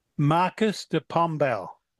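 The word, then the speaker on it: Marquês de Pombal: Marquis de Pombal.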